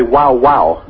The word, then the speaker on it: guau: Dogs, though, in Spain say wow, wow. – No, that's nuts. – Which is spelled G-U-A-U.